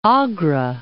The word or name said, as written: Agra